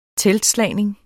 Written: teltslagning

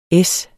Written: es

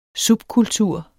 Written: subkultur

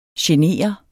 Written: genere

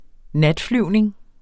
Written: natflyvning

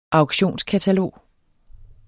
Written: auktionskatalog

